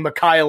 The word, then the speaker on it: Makai: If I said that wrong, please let me know. – It's Makai Lemon. Thank you. I mixed Malachi and Makai too close together, but I'm just saying Makai Lemon, that is a great name.